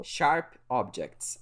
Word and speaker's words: Sharp Objects: E outra série que eu assisti antiga, que eu fui assistir só o ano passado, foi Shark Object. Shark Object. Falei certo? Sharp Objects. É, é isso aí.